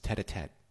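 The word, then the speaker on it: tête-à-tête: I always say this wrong, John. It's tete-a-tete, isn't it? Yes.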